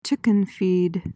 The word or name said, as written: chicken feed